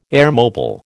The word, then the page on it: airmobile: American English